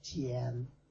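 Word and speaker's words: Thiền: One of the things that's so attractive about Thich Nhat Hanh as a teacher is that his basic lineage is Zen, Tien. The Vietnamese pronunciation of Zen is Tien, T-H-I-E-N.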